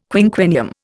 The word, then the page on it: quinquennium: Indian